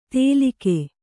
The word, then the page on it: ತೆಲಿಗೆ: telige